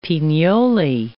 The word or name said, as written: pignoli